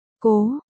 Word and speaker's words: Cố: Lại dạy ngươi nhận chữ, chữ này đọc là cố chính là cố trong Ngô Xuyên Cố Thị của chúng ta.